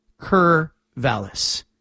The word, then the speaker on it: Corvallis: First started the show, I said Corvallis. And somebody called in and said, it's pronounced Corvallis. And ever since then, I've said Corvallis.